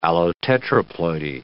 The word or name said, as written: allotetraploidy